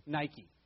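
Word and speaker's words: Nike (νίκη): I'll pronounce it like we pronounce it today. Nike, like the Nike shoes.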